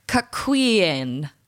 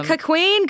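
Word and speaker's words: cuckquean: Cuckween, I believe, is how it's pronounced.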